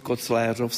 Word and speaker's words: Koclířov: A v současné době se ustálila tedy výslovnost Koclérov s E. – Vždycky bylo Koclérov tady. protože kdysi dávno se použilo kocelery, to byl nejstarší, jako co archiváři psali kronikáři.